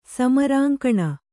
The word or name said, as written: ಸಮರಾಂಕಣ